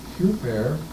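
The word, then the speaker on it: Huper (ὑπέρ): Huper, the Greek says, H-U-P-E-R, as we would spell it.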